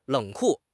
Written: 冷酷

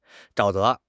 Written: 沼泽

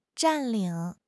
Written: 占领

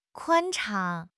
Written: 宽敞

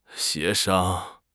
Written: 协商

